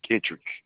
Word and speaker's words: Kitrick: So you said your first name is Kirkpatrick. – Kittrick. – K-I-T-R-I-C-K. Yeah. Okay. It's kind of a tricky pronunciation, I think.